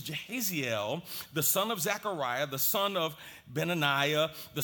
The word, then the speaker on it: Jahaziel: This man, Jahaziel, some would pronounce his name Yahaziel.